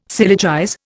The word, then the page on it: syllogize: Indian